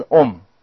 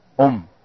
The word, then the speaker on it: Om: 印度的梵文里面呢，第一个字是 Om，诸位可以跟我一起发声， Om 是 M 的音，好， Om。